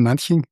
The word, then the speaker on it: Manching: Archäologin in Manching? – Ja, hast du richtig gesagt.